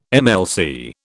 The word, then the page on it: MLC: British English